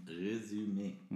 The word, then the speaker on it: Resümee: Und danach kannst du dann Resümee, ich kann dieses Wort nicht aussprechen. Wie heißt das richtig? Resümee.